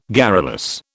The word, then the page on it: garrulous: British English